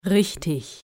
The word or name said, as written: richtig